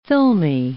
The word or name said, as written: filmy